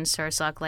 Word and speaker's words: Sursok: Yes, Tam and like salmon, Sursok like sir and the sock on your foot.